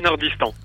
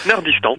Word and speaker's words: N3RDISTAN: N3RDISTAN. – Comment tu dis ça? – N3RDISTAN. – N3RDISTAN.